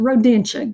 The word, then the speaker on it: Rodentia: They belong to the order Rodentia or Rodinia. No, that's not the right pronunciation. Rodentia, go with that one.